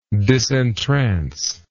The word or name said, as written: disentrance